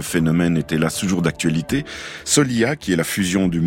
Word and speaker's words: Soliha: C'est Alison, ergothérapeute à l'association landaise de Solia, ça s'écrit S-O-L-I-H-A, qui me pousse à faire un rappel.